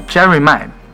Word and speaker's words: Jeremih: 对，就是来自，啊，这个人怎么读？我还特意查了音标，叫 Jerry May。